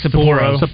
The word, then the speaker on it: Sapporo: Sapporo? How do you say that? – Sapporo.